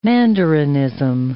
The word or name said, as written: mandarinism